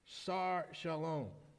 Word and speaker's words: Sar Shalom: Prince of Peace in the Hebrew is, if I can say it right, it's pronounced Sar Shalom. That's the Hebrew.